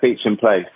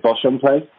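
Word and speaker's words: Beauchamp Place: They set up their own place in Beecham Place, or some people pronounce it Bosham Place, or Bosham Place.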